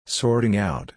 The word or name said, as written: sorting out